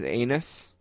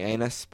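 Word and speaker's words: Anas: They got one of the great freshmen in the country whose name is Anus. It's actually Anus, but you know. – How do you spell it? – It's A-N-A-S.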